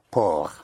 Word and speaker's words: port: How would you pronounce P-O-R-T? P-O-R-T. – Pour. – Oh. Yes, say it again. – Pour, right. So again, the consonant at the end of a word is not sounded unless it is followed by an E. So pour, P-O-R-T, port.